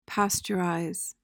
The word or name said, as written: pasteurize